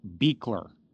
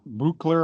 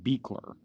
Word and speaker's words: Buechler: The way he pronounced his name, according to Kane Hodder and others, is John Carl Buechler, not Buechler or Bueller or whatever. It's John Carl Buechler.